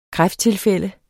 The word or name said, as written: kræfttilfælde